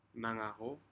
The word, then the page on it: nagaho: Aneityum